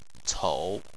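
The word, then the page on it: cou3: tone 3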